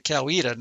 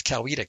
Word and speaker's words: Coweta: Coweta, the local ones pronounce it Coweta. Now, the people looking at that would probably say Coweta, but if you live there, it's Coweta County, and it's the county seat.